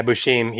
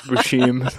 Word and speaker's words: Buscemi: Yeah, Buscemi. He called him Buscemi. – Buscemi. – We're like, Buscemi? – Well, it's Buscemi.